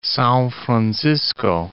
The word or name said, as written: Sao Francisco